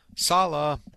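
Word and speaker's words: Saleh: According to all these reports, it is Rob Sala. Rhymes with Hala.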